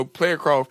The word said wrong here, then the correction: prayer cloth: I'm saying it wrong. I said player cough. – Player cough. – Wow. – I mean, you know, prayer. – Ain't no prayer cloth about to stop you from having cancer.